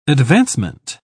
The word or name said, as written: advancement